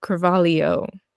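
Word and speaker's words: Cravalho: By the way, her name is spelled, and I've been saying it as Carvalho or Carvalho. But I just watched an interview literally before we got on this call, and she said Carvalho. – So what, Ali'i Carvalho? – I guess.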